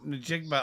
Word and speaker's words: Njigba: Njigba. – Njigba of Ohio State is a wide receiver.